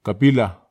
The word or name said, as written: kapila